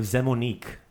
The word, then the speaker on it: Zemunik: So That's kind of that's how I'm guessing, and I know there's probably the city of Zemunik, right? So that the syllable is accented. And that's that I is that long E sound.